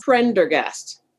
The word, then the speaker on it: Prendergast: It's an Irish name, like the painter Prendergast.